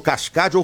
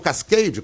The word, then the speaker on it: Cascade: Treinar aqui nesse hotel maravilhoso, no Cascade, ou Cascade, como queiram, né? Que é a pronúncia em inglês, que é o hotel que o Flamengo onde está, onde o Flamengo tá treinando, e é um negócio do outro mundo.